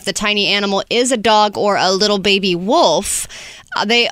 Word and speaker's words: Dogor: D-O-G-O-R. – Dogor.